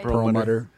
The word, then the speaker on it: Perlmutter: Perlmutter.